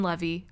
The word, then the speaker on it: Levy: Basically, as just a quick recap, this is the show starring Jane Levy and or Levy, never sure how to pronounce the last name spelled L-E-V-Y. I only am confident because I've heard them say it, Eugene and Dan Levy of Schitt's Creek. It's Levy.